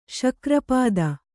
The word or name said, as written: ಶಕ್ರಪಾದ